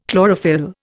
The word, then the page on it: chlorophyll: Indian